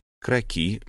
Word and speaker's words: кроки: Поэтому в число непременных умений каждого офицера, наряду с фехтованием, верховой ездой и бальными танцами, входили навыки изображать кроки местности. Кроки — слово французское. Не склоняется и произносится с ударением на последнем слоге.